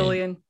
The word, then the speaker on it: Fillion: Fillion.